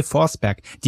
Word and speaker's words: Forsberg: Und da hieß Forsberg ohne Witz Forsberry ausgesprochen. – Barry. Total. – Ja, Barry, wie Beere, Barry.